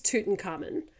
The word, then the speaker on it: Tutankhamun: I did look pretty much all of the pronunciation, either videos or the phonetic spelling. It is Tutankhamun. However, I watched a National Geographic documentary on Disney, and they would say Tutankhamun, which just didn't sit with me.